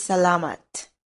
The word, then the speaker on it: salamat: In Filipino, thank you is salamat. Salamat. Let's break it down by syllable. Sa, la, mat.